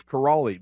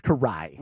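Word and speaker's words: Kiraly: I thought it was Karali because that's how it's spelled. There's an L there. – Yes. – He goes, Karch Karai.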